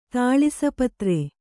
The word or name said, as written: ತಾಳಿಸಪತ್ರೆ